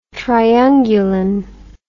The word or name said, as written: triungulin